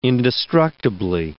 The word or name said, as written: indestructibly